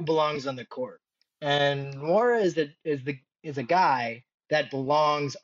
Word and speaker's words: Nwora: Funnily enough, you threw me a curveball and wanted to talk about this year's number 45 selection, Jordan Nwora, is that how you say his name? – Yeah, I'm learning it myself. I believe you had it correct. Nwora.